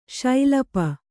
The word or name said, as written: ಸಲಪು